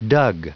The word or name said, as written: dug